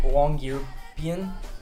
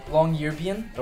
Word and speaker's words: Longyearbyen: In Norway, in Longyearbyen, like that's how you pronounce it. – Sorry, what was that again? – Longyearbyen.